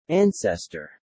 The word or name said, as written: ancestor